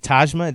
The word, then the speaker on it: Tajma: So is it Tajma? Did I pronounce it right? – Yes, like Taj Mahal.